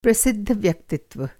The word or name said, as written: व्यक्तित्व